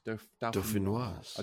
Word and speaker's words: dauphinoise: You don't pronounce it yet. It's Dauphinois. Yeah. Dauphinois, yeah, potato. I don't think I've even spelt it correctly, but it's Dauphinois.